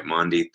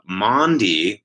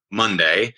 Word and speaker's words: Maundy: What is M-A-U-N-D-Y, Maundy Thursday? Okay, now I'm going to give you a little lesson here. So Maundy, that word, it's not Monday, it's Maundy, and it comes from the word mandatum.